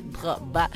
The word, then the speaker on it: Drogba: Figuras como Will Smith, LeBron James, Rafael Nadal, Tom Brady, Didier Drogba, eu quero dizer mesmo assim a pronúncia, Drogba, vão estar em Luanda então para este evento.